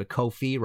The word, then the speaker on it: Ko-fi: If you did like the show and want to support future episodes, please go to Ko-fi, rhymes with no fee, .com.